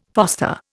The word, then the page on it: foster: Indian